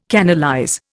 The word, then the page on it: canalize: Indian